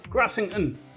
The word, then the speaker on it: Grassington: Grassington. – No, I think Ronnie said we have to pronounce it with a northern accent, you know, so Grassington. You try it. – Grassington? – Very good.